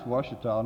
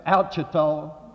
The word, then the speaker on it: Ouachita: But it's spelled O-U-A-C-H-I-T-A. It's an Indian name, and it's on the Ouachita River.